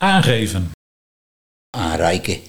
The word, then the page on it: Anreike: Nieuw lekkerlands